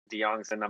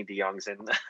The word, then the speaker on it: Diongzon: If you type in my name, Sidney Diongzon, I'm pretty sure I'll be the only Sidney Diongzon on YouTube.